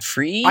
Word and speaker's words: frieze: Ah, the Google tells me it's frieze is the pronunciation.